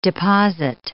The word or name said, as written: deposit